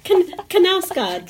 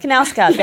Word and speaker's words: Knausgaard: Carl Ove Knausgaard? Ove? – Yep, that's perfect. – Thank you. – It's perfect. – Oh, great. – It is. Knausgaard. – Knausgaard, that's right.